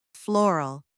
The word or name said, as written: floral